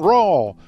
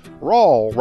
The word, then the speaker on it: Rawl: I'm your host, John Rawl. Rawl rhymes with y'all.